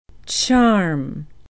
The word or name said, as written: charme